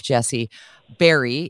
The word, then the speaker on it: Bahrey: Jesse Barry is her name. It's spelled B-A-H-R-E-Y if you're going to look her up on your social media.